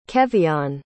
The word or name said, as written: kevion